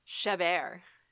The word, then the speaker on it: Chabert: How are you supposed to say it? – Chabert. – Yeah. – Lacey Chabert.